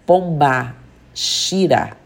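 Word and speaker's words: Pombagira: A pronúncia dada é Pomba Shira.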